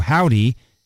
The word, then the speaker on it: HOVVDY: but it's spelled H-O-V-V-D-Y, kind of, if you get it, that V-V is like a W, Howdy, and the show is at the Chapel, Monday, October 19th at 8 o'clock, doors open at 7.